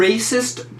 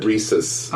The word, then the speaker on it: Rhesus: The Racist Chart. – Rhesus. – Oh, the Rhesus. Like Rhesus pieces? – Like Rhesus monkey. It's spelled different than Rhesus. Not Rhesus. Rhesus. It's R-H-E-S-U-S.